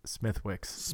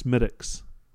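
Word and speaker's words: Smithwick's: I learned years back to not call it Smithwix. – Smithwix. Yeah, that's... – How you pronounce it? – It's Smittix. – But it's spelled like Smithwix.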